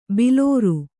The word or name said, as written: ಭಲರೆ